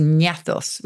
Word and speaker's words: gnathos: So ganache actually comes from the Greek for jaw, which is gnathos with a silent G, G-N-A-T-H-O-S.